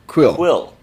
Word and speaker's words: Kuiil: K-U-I-I-L? – Quill. – That's right. Quill. – I think it's Quill. It might be. I don't remember. – Well, that's how it's spelled, but yeah, I think they pronounce it Quill.